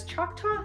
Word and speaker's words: Choctaw: He has Choctaw and Cherokee descent.